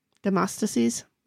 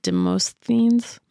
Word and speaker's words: Demosthenes: Is that how the audiobook said it? – Demosthenes. – Mm-hmm. – Mm-hmm. – I have been pronouncing it Demosthenes. – I mean, that's what it looks like.